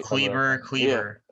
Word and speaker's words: Kleber: It's just – and look, Kubler, if that's how you pronounce his name, Tim Hardaway. – Clubber. – Brunson. Oh, is it Clubber? – Clubber or something like that. – Okay. – Kleber.